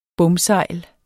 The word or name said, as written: bomsejl